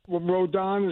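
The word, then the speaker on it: Rodón: And it looked like the Rodon, is that the way you pronounce the pitcher? – It is.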